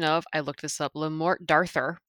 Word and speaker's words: Le Morte d'Arthur: Le Morte d'Arthur or something? – D'Arthur. It was actually D'Arthur. It was a lady with an English accent, so I assume she knew what she was talking about.